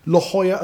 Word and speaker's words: La Jolla: La Jolla as well.